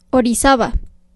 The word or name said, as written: Orizaba